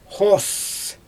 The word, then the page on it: xoos: Xyzyl